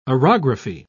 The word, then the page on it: orography: British English